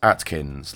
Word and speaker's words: Atkins: My full name is Chris Atkins, like the diet.